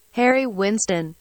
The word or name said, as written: Harry Winston